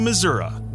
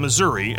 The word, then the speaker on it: Missouri: Wheels down Kansas City, Missouri. Not Missouri, as I've been mispronouncing it for the last 3 decades.